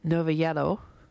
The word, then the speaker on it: Novallero: Okay, before that, the Pointed Sticks, we heard Novalero, or excuse me, I do believe the correct pronunciation is Novallero, which I'm sorry if I've mangled that Spanish word.